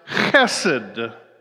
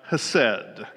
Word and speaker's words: chesed: It's pronounced chesed, not hased.